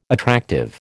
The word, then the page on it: attractive: American English